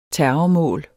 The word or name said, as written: terrormål